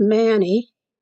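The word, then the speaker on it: Mani: My last name is Manny, spelled M-A-N-I. I've been asked if my name is pronounced Manny. I've had it pronounced that way, or Manny.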